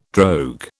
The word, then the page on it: drogue: British English